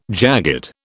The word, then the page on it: jagged: British English